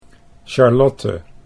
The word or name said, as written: Charlotte